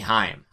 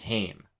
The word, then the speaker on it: Haim: Bulking Season, the team of Arthur MacArthur and Chuck Stone, taking on The Production, the team of Derek Dillinger and Ziggy Haim. Ziggy Heim. I hear that one pronounced both ways so much. I believe the actual correct pronunciation is Haim. So I'm going to stick with that.